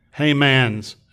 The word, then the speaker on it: Heymann: Heymann's, and that's how you pronounce it according to his son, Heymann's nephritis, which is FX1A, Brush Border antibody, now known as GP330, Megalin, as seen in membranous glomerular nephropathy.